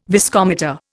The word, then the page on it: viscometer: Indian